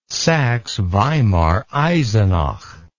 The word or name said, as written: saxeweimareisenach